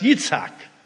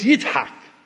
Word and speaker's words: Yitzhak: It's not pronounced Isaac, it's pronounced Yitzhak. In Arabic, it's Yitzhak.